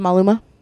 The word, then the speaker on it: Maluma: Is it Maluma? – Yes.